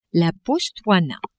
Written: Botswana

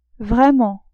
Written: vraiment

